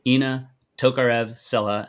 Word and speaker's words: Ina Tokarev-Sela: Now, my guest today is CEO and founder of Illumix.ai, Ina Tokarev-Sela.